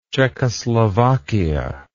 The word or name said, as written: czechoslovakia